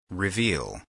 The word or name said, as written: reveal